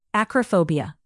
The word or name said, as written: acrophobia